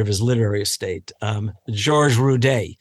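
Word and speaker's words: Rudé: But when he went to school, he realized the best thing he could do was add an accent on the E and give it a kind of French sound, Rudet.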